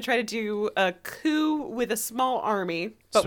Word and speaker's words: coup: They're going to do a coup Riverdale. – Fuck yeah, Riverdale coup. – It is 100% impossible for me to not pronounce the P in coop.